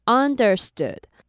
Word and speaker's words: understood: 第一个字是 understood, understood。后面那个 oo 呢，你尽量不要念成完全的 u，其实会比较偏向 stood 的音。